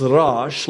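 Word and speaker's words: Rosh (ראש): In my native language of Arabic, we say Ras. In Hebrew, it's Rosh, like Jesus in Arabic is Yeshua, and in Hebrew, it's Yeshua. So they pronounce the sh, and we pronounce with the s.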